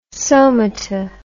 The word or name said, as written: somata